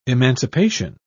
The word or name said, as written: emancipation